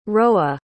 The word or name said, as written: rowa